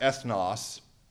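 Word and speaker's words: ethnos (ἔθνος): All right, and this is pronounced, I have it in parentheses there, it's pronounced ethnos.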